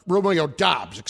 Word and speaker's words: Doubs: It was Aaron Rodgers pointing out his rookie wide receiver, Romeo Dobbs, who is doing, quote, Romeo Dobbs, it's pronounced, D-O-U-B-S. – Yeah. – Pronounced that way.